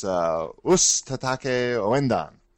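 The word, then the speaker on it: Osu! Tatakae! Ouendan: Osu-tataki. – Oh, you're going to butcher it, dude. – Osu-tatake Owindan. – Not bad. That's Osu-tatake Owindan.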